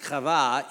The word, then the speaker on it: Chava: A man whose name is Adam, in Hebrew means human, and a woman whose name Eve, pronounced Chava in the Hebrew, her name means life.